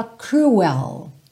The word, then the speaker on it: cruel: It's cruel Cuidado com essa palavra porque você não fala cruel, ok? Então tem esse é, a palavra é escrita exatamente igual no português, mas a pronúncia é diferente, tá? Então você faz cruel, cruel, without you here beside me.